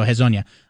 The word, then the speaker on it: Hezonja: So Hezonja.